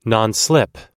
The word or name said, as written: nonslip